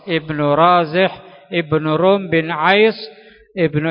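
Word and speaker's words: Razih (رازح): Bin Razih. Bin Razih. Ra-nya panjang. Zai kemudian ha.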